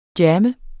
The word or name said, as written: jammer